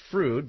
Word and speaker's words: Freud: It's spelled Freud, but it's pronounced Froude because they had a falling out.